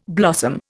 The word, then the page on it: blossom: Indian